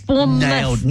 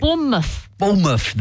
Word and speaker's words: Bournemouth: Bournemouth. – Nailed it. – Bournemouth, there you go. – Well, somebody needs to tell them that's not how you pronounce it because it's got a TH on the end.